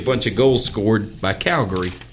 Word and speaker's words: Calgary: I think that's how they say it there. Calgary.